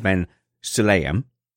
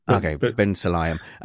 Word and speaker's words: Ben Sulayem: It's Mohammed Ben Sulayem. Ben Sulayem. – It can be Bin or Ben. – Ben Sulayem.